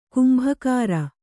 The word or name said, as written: ಕೆಂಬೊಗರ್